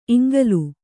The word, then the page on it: ಇಂಗುಲೆ: iŋgule